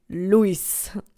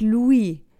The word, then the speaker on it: Louis: Mein Papa sagt trotzdem Luis. Ja, natürlich, ne? Die Spanier können nicht Louis schön französisch aussprechen. Nein, nein, nein, das geht nicht. Es ist Luis.